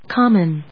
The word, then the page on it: common: com・mon; kάmən ｜ kˈɔm‐